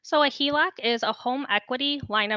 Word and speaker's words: HELOC: And that is HELOC. I said it right this time.